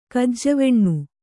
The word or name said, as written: ಕಜ್ಜವೆಣ್ಣು